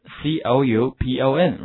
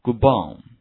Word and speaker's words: coupon: 好，那么下面看一下购物券礼券这个词 coupon, right? 这个 OU 发 U 的音哈，跟我读一下 coupon, OK, next word, 好，柜台 counter, 这个 OU 的发音大家注意了，一定是发的非常饱满的元音 OW 这个音， OK, 把声音往后靠，跟我读一下 OW Ow, counter, OK, good.